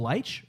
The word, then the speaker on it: Leitch: No, it's Will Leitch. Is it Leitch? Is that how you say his name? – Yeah, Leitch, I think.